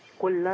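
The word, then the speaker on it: كلٌّ: لا ده تنوين. كل آمن. – أيوة. – ده تنوين بالضم. – أيوة. – اللام مضمومة والضمة الثانية هي بيان لوجود التنوين، النون الزائدة.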